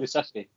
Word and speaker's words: Kulusevski: God knows how that's pronounced, but I think it is Kulusevski, isn't it? It is, Kul- it right. – Kulusevski.